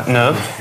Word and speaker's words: neuf: Un, une, deux, trois, quatre, cinq, six, sept, huit, neuf. Или не читается, да? Neuf. Neuf, dix, onze, douze.